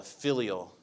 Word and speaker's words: filial: Filial. I said it right. Okay, good. I found out that I've been mispronouncing that word for the last couple of months. I've been saying it a lot incorrectly, but it's a filial fear.